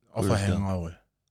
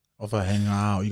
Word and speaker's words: Ofahengaue: Yes, you're pronouncing it right. Ofahengaue. Ofahengaue. You got to like kind of let it roll off your tongue.